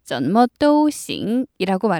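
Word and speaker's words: 怎么都行: 자, 중국어로는 전머도싱이라고 말하는데요. 단어의 뜻을 살펴볼게요. 전머 하면 제가 몇 번 전해드렸어요. 어떻게, 어떤 이란 뜻이고요. 모두라는 뜻은요. 또라고 합니다. 그리고 제일 중요한 단어 좋다, 괜찮다, 충분하다는 싱 이렇게 발음하시거든요.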